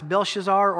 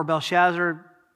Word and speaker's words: Belshazzar: In the third year of the reign of Belshazzar, by the way, it's Belshazzar or Belshazzar, you can take your pick on the pronunciation, the king of Babylon, he had inherited from his grandfather, Nebuchadnezzar.